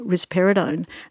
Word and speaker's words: risperidone: And there are much better ways of treating agitation than throwing risperidone and writing a script for risperidone. – Is that how you pronounced it? Right. – Risperidone.